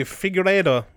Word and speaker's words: Figueiredo: It'd be interesting to see these guys like Figueroa, how do you say that? – Hey, Figueiredo, isn't it? – Figueiredo 15-0, like, against Formiga.